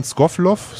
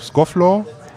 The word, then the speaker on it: Scofflaw: Scoffloff, Scofflaw.